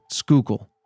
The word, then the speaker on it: Schuylkill: Yeah, it's S-C-H-U-Y-L-K-I-L-L. – Schuylkill. – Schuylkill. – All right. – Or Schuylkill is the other pronunciation we saw online.